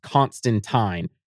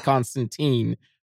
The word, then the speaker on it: Constantine: But yes, the one thing that people have bitched about with Constantine, especially British people, is because Constantine is British, it is pronounced Constantine. But as an American, I've always said Constantine.